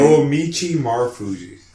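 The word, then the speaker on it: Naomichi Marufuji: Noamichi Marufuji.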